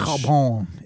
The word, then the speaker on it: Chabon: Next week, Andrew is reading The Yiddish Policeman's Union by Michael Shabam. Then how much Chabon is pronounced Chabon.